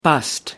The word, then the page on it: bust: American English